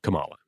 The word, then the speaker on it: Kamala: It is pronounced Kamala in this episode, who has arrived at the lunar station to jump to a distant world where she's going to study an alien civilization.